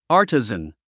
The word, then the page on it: artisan: άːrtəzən; アーティザン